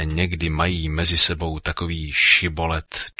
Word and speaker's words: Šibolet: Jestliže odvětil, nejsem, vyzvali ho, tak řekni Šibolet. On však řekl Sibolet a nedokázal to přesně vyslovit. Tu ho popadli a zabili při jordánských brodech. Toho času padlo z Efraima 42 tisíce mužů. Galáckým se podařilo efraimské porazit. Obsadili jordánské brody a efraimští nemohli utéct. Pak našli zvláštní poznávací heslo, které efraimští neuměli vyslovit, protože obsahovalo š.